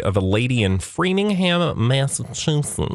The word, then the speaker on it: Massachusetts: We're going where dumb people pronounce it Massachusetts. – Mm. – But it's really Massachusetts.